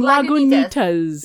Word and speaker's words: Lagunitas: Lagunitas! – Did I pronounce that totally wrong? – Lagunitas.